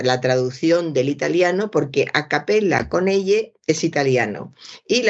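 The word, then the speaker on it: a cappella: Para empezar, he visto en un diario cantar a capela capela escrita con ella eh, a capella, a capella, es escrito con ella que sería, eh, se no pronunciaría o sería a capella, no se pronunciaría así porque en italiano dicen a capella,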